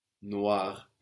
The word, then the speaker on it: noir: We use this in, for example, film noir, no? Black film. Or in fashion or fragrances, where we have a lot of French commercialized. This is spelled N-O-I-R, noir, black. Or maybe you've seen le chat noir,